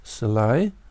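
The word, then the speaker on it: Selye: So then we can see that it is that that level, which in medicine was described by Hans Schlei, Slavic pronunciation, and he was working on what happens when health is changed.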